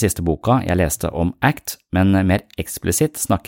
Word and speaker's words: ACT: ACT står for Acceptance and Commitment Therapy, og uttales som det engelske ordet ACT.